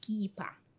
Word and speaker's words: kippa: Pronounced kippa.